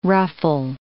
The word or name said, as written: ruffle